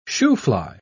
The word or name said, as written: shoofly